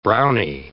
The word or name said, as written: brownie